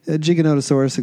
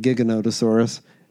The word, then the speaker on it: Giganotosaurus: Oh, Giganotosaurus, except in this movie they pronounce it Giganotosaurus.